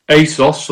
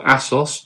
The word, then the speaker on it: ASOS: ASOS, or ASOS, depending on how you want to pronounce it, and Dunelm as well.